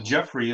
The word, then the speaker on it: Jeffrey: And then Jeffrey is my middle name. And the reason I chose my middle name, J-E-F-F-R-E-Y, is my wife has always spelled my middle name wrong.